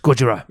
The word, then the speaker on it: Gojira: I think I've like one of the recent Godzilla movies, the way they pronounce it is Gojira.